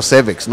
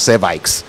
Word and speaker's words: cervix: That's cervical, not cervical. Just say it right, yes. The thing is your cervix, not your cervix.